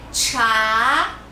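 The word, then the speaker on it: chá (茶): É chá, chá. O CH nós pronunciamos como se fosse TCH e a gente sobe um pouquinho a entonação.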